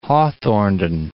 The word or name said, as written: Drummond of Hawthornden